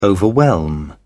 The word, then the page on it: overwhelm: British English